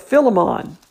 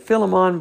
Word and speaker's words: Philemon: I believe next time, tomorrow, we're studying the book of Philemon. Or I always grew up pronouncing it Philemon, but McGee pronounces it Philemon.